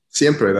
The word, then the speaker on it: siempre: Yeah One of the tracks that I've really enjoyed is, I'm sure I'll mispronounce it, Siempre? – Siempre, that's it.